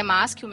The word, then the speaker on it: Maschio: É, o meu sobrenome, a pronúncia dele é Maschio mesmo, ele é, ele é italiano. Então, você pronunciou ele corretamente.